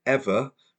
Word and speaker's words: Eavor: So this is about a company called Ever, pronounced E-A-V-O-R.